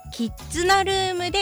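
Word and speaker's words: 絆ルーム: はい、まずはですね、うん、キッズナルームで家族でニコニコを、絆をキッズナって読むとか面白いですね。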